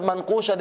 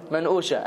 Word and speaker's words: manqusha: But Lebanese people, they don't pronounce the qaf. The qaf is a hamza. Middle Eastern people in general, Egyptian as well, the qaf is a hamza. So instead of manqusha, they called it manusha.